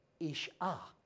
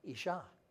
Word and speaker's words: isha: Isha. Isha.